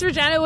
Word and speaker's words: Regina: that it's Regina, people. – It's Regina with an R. – It just rhymes with fun, you know.